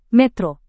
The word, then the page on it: metro: Indonesian